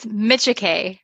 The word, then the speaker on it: Micciche: You are so close. It's Micciche.